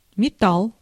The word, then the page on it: металл: /mʲɪˈtalː/